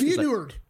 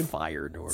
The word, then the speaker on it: Feyenoord: Feyenoord. – What is it? – Feyenoord for fire nudes. Like I said last time, Kelly Paul Lozada gave out this team. – Feyenoord. – Fire nudes. – Feyenoord against Inter Milan.